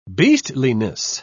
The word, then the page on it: beastliness: British English